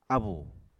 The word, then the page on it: abù: Apatani